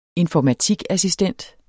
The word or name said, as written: informatikassistent